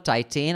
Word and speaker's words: Titane: Uh, then a film called Titane.